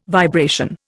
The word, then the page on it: vibration: Indian